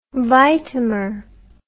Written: vitamer

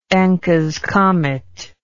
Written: enckes comet

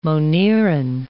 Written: moneran

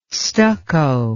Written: stucco

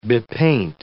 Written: bepaint

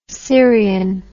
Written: sirian

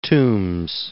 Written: Toombs

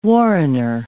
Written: warrener

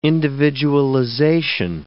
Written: individualization

